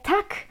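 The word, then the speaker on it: tack: A wzięło się to stąd, że nasze polskie tak wymawia się dokładnie tak samo jak szwedzkie tak, co oznacza dziękuję.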